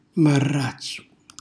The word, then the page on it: marrazzu: Calabrese